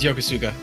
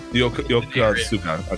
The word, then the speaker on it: Yokosuka: Yosikawa or something like that part? – It was Yokosuka. – Yokosuka. Okay, yeah, good. I just strung four syllables and hoped I hit the right way.